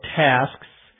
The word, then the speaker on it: tasks: Notice in pronouncing it very carefully, I have an S, a K, and an S sound at the end. However, in normal pronunciation, the plural of tasks would probably come out something closer to tasks. I have a bunch of tasks to do today. You can almost not even hear a K or not hear a K at all.